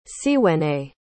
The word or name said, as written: cwene